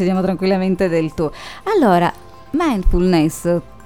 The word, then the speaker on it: mindfulness: Oggi parliamo, questa settimana mi hanno messo la dura prova con i termini, spero questa volta di dirlo bene, mindfulness, l'ho detto bene dottoressa? – Sì sì.